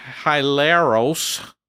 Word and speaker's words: hilaros: The Greek word used in there is hilaros, and if that to you seems like it rhymes with hilarious, that's because that's where that word comes from.